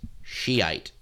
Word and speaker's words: Shiite: Yeah, just for clarification, the correct pronunciation is Shiite.